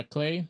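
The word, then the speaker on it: Klei: And so I'm thinking even games like Clay, yeah, Clay, that's how you say it, they've been in, yeah, Clay Entertainment.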